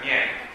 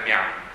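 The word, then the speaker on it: Mien (面): Ricordo che I-A-N si pronuncia Yen, non Yan. Quindi quello è Mien, non è Mian.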